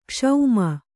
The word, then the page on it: ಕ್ಷಮಿ: kṣami